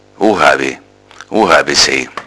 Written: ohawe

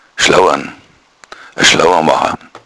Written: schlouan

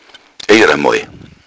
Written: iaramoi

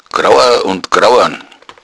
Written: groua

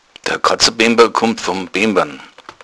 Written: katzabemba

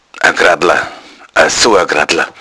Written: graddla

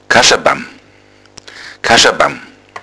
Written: kaschabam

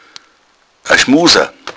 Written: schmuser

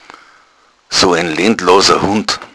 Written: lentlos

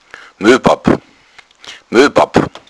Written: moepapp

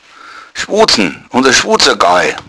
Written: schwuzn

